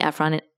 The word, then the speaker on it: Ephron: I have been pronouncing Ephron wrong my entire life. So it's Ephron. I looked it up.